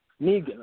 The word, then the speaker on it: Negan: Negron. I think is how they pronounce the guy. He's like a gang. Negan, right? Thank you.